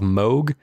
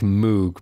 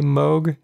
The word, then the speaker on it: Moog: is the synthesizer brand called Moog. I know it looks like Moog, but actual synthesizer fans get rather cross with you when you pronounce it that way. So I'm going to say Moog.